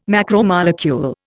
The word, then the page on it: macromolecule: Indian